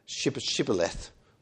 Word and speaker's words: Shibboleth: they're given this little test where they're asked to say Sibboleth. And if they say Shibboleth, but if they say Sibboleth, they're struck down, aren't they? It's a regional pronunciation.